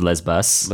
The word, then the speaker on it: Lesbos: Google, uh, I googled the pronunciation and it was Lesbos.